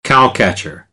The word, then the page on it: cowcatcher: American English; ɚ